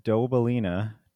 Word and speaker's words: Dobelina: And we have Bob Dobelina. How actually is that pronounced? – Bob Dobelina, like the song.